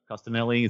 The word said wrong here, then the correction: Coscarelli: is that how you say it? – How do you say it? Coscarelli. – Coscarelli.